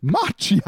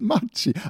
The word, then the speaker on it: Maggi: Du hast doch keine Ahnung, wie wir Schweizer Matschi aussprechen. Es heißt Matschi.